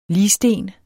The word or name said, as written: ligsten